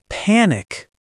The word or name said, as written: panic